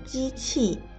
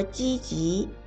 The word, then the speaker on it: 积极: 你刚才说我很机器，不应该念机器，而应该说积极。